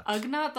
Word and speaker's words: Ugnaught: And he's also got, like, a little, I think, Ugnaught? Is that how you pronounce them? – Ugnaught, the little, like, pigman friend named Turba.